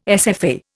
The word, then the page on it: SFA: Indian